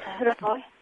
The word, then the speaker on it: Rajoy: İspanya Başbakanı da Mariano Rajoy mu nasıl okunuyor bilmiyorum. Rajoy belki. – Evet, Rajoy.